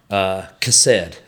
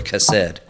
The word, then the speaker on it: chesed: And so we see that, and we see that in Genesis 6 where Noah found favor, grace, chesed is the Hebrew word chesed. – Chesed, which is, you got to pronounce it like you're hocking a loogie, like chesed.